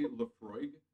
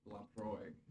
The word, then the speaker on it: Laphroaig: So I had been pronouncing it wrong. It's apparently Laphroaig. – Ooh, I can say that. Laphroaig. That's good. Yeah. All right. – How were you pronouncing it? – Laphroaig.